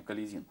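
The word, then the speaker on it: Каледин: А донской атаман Калидин, правильно, кстати, это вот ударение, Калидин, донской атаман Калидин считает, что нельзя давать им официального прикрытия.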